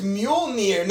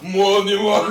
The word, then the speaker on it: Mjolnir: It's Mjolnir, not Mjolnir. – You gotta pronounce every letter in the way. Mjolnir. You gotta pronounce every letter in it. This man put a hard J on it.